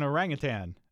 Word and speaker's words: orangutan: It's an orangutan. There's no G on the end.